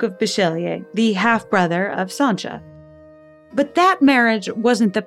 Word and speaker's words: Sancia: a girl named Sancha, although seeing her name spelled out, S-A-N-C-I-A, you might be forgiven for mispronouncing it Sansa.